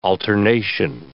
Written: alternation

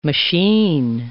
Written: machine

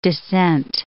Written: descent

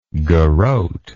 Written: garrotter

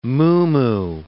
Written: muumuu

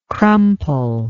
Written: crumple

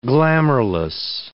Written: glamourless

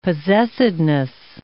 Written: possessedness

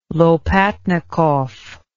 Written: Lopatnikov

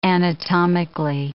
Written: anatomically

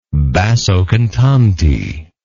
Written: basso cantante

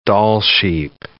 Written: Dall sheep

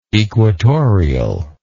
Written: equatorially

